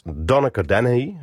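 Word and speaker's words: Donnacha Dennehy: Afgelopen vrijdag verscheen een nieuw album met muziek van de Ierse componist Donnacha Danahy.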